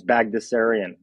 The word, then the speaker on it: Bagdasarian: So my last name has a lot of letters in it, but it's actually relatively simple to pronounce. It's Bagdasarian. So I'm Patrick Bagdasarian.